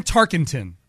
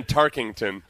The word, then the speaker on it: Tarkenton: How do you pronounce his name? – Fran Tarkenton. – Oh, I thought you said Fran Tarkington.